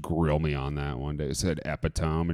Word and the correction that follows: epitome: and she's like, you mean epitome?